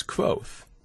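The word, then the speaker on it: Kvothe: The very first line of the book that I initially wrote was, "My name is Quoth," pronounced nearly the same as Quoth, like Quoth the Raven.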